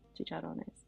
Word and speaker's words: chicharrones: Chachoyes with white rice. – Chicharrones. – Am I saying it right? Chachachones? – Chicharrones.